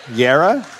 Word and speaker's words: Yarra: Yarra.